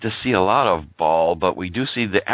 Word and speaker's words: Baal: And I guess is it pronounced Baal or Baal? – Baal. – Baal, okay. – Baal, yeah.